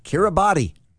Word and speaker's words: Kiribati: Kiribati. – Okay. Kiribati, okay.